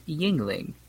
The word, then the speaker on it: Yuengling: It's pronounced Yuengling, and it's the anglicized version of Yuengling, which is, it has umlauts. When they came to America, they got the umlaut out.